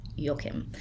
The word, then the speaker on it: Joachim: I'm pretty sure I've listened to people talk about him, actual German people talk about him, and I'm pretty sure we call him Joachim. So that's what I'm going to call him, Joachim, spelled J-O-A-C-H-I-M. I would have pronounced it as an English person, Joachim, but we're going to go with Joachim.